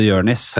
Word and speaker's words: Jonis: det bommer nok de fleste, for det skrives Jonis, og så er ø, somalisk uttalelse Jørnis. Ja. At, at O O blir ør.